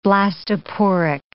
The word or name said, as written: blastoporic